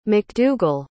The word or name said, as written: makdougal